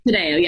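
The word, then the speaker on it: Taddeo: Tadejo, yes.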